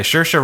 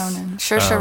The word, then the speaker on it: Saoirse: Oh, Saoirse Ronan. – I don't know. Yeah. – Is that how you pronounce it? – I've never attempted to pronounce it. – Like inertia.